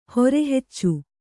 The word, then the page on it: ಹೆರೆಹೆಚ್ಚು: here heccu